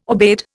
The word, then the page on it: aubade: Indian